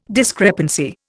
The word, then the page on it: discrepancy: Indian